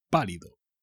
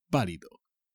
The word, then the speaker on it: pálido: Pálido. Pálido. Este tío viene del norte y como hay menos sol, pues tiene la piel pálida. Es un tío pálido. Tiene un acento en la A. Cuidado.